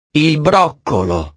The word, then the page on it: il broccolo: italiano